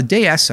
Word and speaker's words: DS: Now, the DS, a clever play on words that is pronounced the same as the French word for goddess, was the first mass production car to feature hydropneumatic suspension and disc brakes.